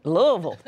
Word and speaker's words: Louisville: And you said it right, Louisville.